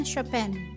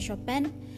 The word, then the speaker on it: Chopin: Bukan Chopin juga, tapi bacanya Chopin."